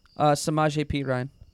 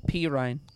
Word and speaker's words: Samaje Perine: Samaje Perine. Go to tight ends. – Say his last name again. – Perine.